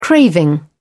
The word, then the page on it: craving: British English